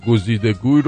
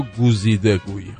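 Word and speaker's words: گزیده‌گوی: جمله کمگوی و گزیدگوی رو با تلفظ اشتباه بهش یاد دادن میگه کمگوی و گوزیده گوی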